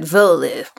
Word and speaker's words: Werle: He said Werle. And I was like, okay. But right. it's Werle's the family name. It's how the family pronounces it.